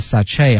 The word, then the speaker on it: sachet: 同时啊，同学们注意这个词的发音和它的拼写呢还有一定的不同。它的拼写呢是 sachet，但是呢在发音的时候这个 T 呢是不能发音的哈。它的发音有两个，一个是 sachet，还有一个 sachet 哈，一个英式的一个美式的都可以，但是呢有一点后面这个 T 是不能发音的哈，表示那种比较小的一些小袋子。